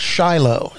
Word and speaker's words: Chilo: When you travel the beautiful Ohio River Scenic Byway, you'll pass through a little town called Shiloh, spelled C-H-I-L-O. We don't know why the name's pronounced this way, but we do know another town in Ohio near Coshocton that's spelled C-H-I-L-I and pronounced Chili.